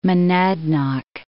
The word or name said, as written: Monadnock, Mount